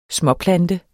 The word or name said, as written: småplante